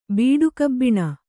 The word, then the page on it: ಬೀಡುಕಬ್ಬಿಣ: Kannada